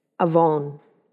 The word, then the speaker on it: avon: For the term iniquity, how you pronounce it in the Hebrew is avon. Avon.